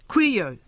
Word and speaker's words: Couillault: Couillault.